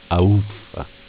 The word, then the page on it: A uffa: dialetto